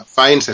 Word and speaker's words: Fiennes: O sea, Fines es Fines, como de, toda la vida, Fines.